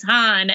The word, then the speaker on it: Han: I loved the moment where he goes Han, and Han goes, "It's Han," and I was like, "Thank you."